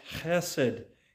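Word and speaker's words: chesed (חסד): Whenever the word love shows up in Psalm 119 and the subject is the Lord, it is a word that is pronounced chesed in the original language, and it is a word that speaks of God's covenant faithfulness.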